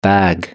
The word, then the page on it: bag: British English